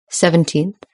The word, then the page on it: seventeenth: British English